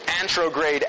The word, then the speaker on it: anterograde: Antrograde, after. Right? Anterograde or antrograde. I think I've seen it spelled both ways and pronounced both ways.